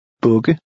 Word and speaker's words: bugge: Here the vowel sound is short because it's followed by a double consonant. Try it. – Bugge.